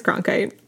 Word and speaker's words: Cronkite: Cronkite? – I think so. – I don't know how to say his name. – It's Cronkite.